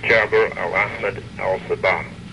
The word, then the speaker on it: Jaber al-Ahmed al-Sabah: how do you pronounce that? – Sheikh Jaber al-Ahmed al-Sabah.